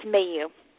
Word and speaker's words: Maillous: It's Maillous.